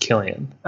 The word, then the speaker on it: Cillian: And according to Wikipedia, it's pronounced Killian. – Oh, okay. It's Cillian Murphy.